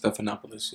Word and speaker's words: Stephanopoulos: And it's called The One with George Stephanopoulos? – Stephanopoulos, yeah.